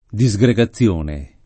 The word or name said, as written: disgregazione